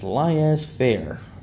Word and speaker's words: laissez faire: But the man who I heard say it, Samuel Dish, is one of the smartest men when it comes to American government and economics I've ever known. He says it's laissez faire. As far as I'm concerned, that's how it's pronounced.